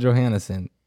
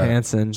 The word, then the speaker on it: Johansson: But Black Widow, Scarlett Johansson, is the porcupine. – Yeah. You said her name wrong, but yeah. – I did. – I did not know that. – Johansson.